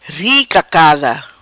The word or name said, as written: Ri cacada